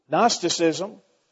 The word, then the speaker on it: Gnosticism: Now, it's like gnome. It's got a silent G. You kids are taking notes. It's G-N, Gnostic. Gnosticism was a heresy of that time, and it's a heresy that still lingers today.